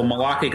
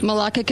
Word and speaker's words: Malacca: including the nationally best-selling novels Treason and Malacca? Is that how you say it? – Conspiracy? That's right. It's a mouthful. Malacca Conspiracy. – Malacca Conspiracy.